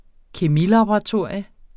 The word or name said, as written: kemilaboratorium